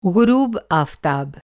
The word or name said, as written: ग़ुरूब आफ़ताब